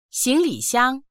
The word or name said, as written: xing2li3xiang1